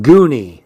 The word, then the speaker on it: Gunny: Gunny pronounced her name so that it sounded like Goonie.